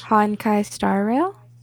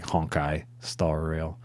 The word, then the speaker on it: Honkai Star Rail: Honkai Star Rail? – Yeah, basically. It's like Honkai Star Rail.